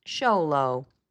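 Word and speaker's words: Xolo: Floki is, in fact, a Zolotquitl, lovingly called a Zolo, pronounced Sholo.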